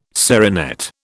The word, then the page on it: serinette: British English